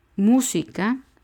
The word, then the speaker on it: música: Música es un ejemplo. Lleva el acento en mu, en la u. Son tres sílabas, música. Y la tercera de derecha a izquierda es mu, música.